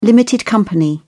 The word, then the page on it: limited company: British English